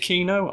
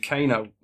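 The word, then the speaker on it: Keno: Is it Kino? I was been calling him Kano, like that guy out of Mortal Kombat. – It's K-E-N-O. But everybody pronounces it like Kino, like it might have an I instead.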